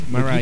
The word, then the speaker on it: Mariah: El María en inglés, que se escribe como María H, que lleva pronunciaciones totalmente extrañas. – Mariah. – Es Mariah. Quita la dulzura del María, claro.